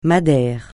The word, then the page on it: madère: francais